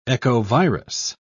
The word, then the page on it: echovirus: British English